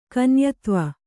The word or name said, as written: ಕನ್ಯತ್ವ